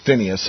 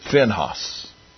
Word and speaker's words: Phinehas: I've always been taught it was Phinehas. Some people pronounce it Phinehas.